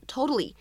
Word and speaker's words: totally: 这个单词呢当中存在一个在美式发音当中呢所独有的 flap t 闪音，相信各位同学呢应该已经听出来了。那么这样一个单词 totally，它前面呢又加上了一个 just，此时呢又形成了一个非常典型的失去爆破的处理，所以呢我们可以读成 just totally，just totally。